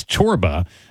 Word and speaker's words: Csorba: His name is Thomas Chorba. That's spelled C-S-O-R-B-A.